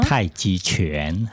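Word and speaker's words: Tai Chi Chuan: Alors, je voudrais revenir également sur le fait que vous enseignez également le Tai Chi Quan. Je ne sais pas si je prononce bien. – Tai Chi Quan.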